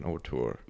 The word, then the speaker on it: auteur: And you mentioned Martin Scorsese as a, how do you pronounce that, auteur? – Auteur, yeah. – The French term.